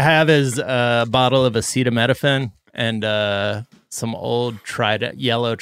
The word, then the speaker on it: acetaminophen: Wait, that's how you say it? What is it? – Acetaminophen? – I love Kirkland brand. – I thought it was Ace Tammy Nofen.